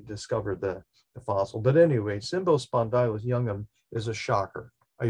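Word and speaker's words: Cymbospondylus: So Symbo, which actually I think I have it correct in the pronunciation, Symbo, C-Y-M-B-O, Spondylus, S-P-O, and D-Y-L-U-S, and then the second part of the species genera name is youngum.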